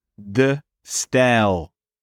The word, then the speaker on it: De Stijl: How do we say it? Mondrian? – De Stijl. – Okay. – Style. Like English style.